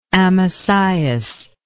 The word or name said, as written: Amasias